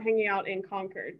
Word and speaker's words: Concord: Concord, a little bit more of an R. – The R sound, so Concord.